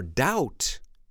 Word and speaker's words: doubt: Now remember, doubt is spelled D-O-U-B-T. The B is silent.